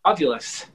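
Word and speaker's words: Ovilus: Am I pronouncing it right? It's the device that Yeah, the ovulus.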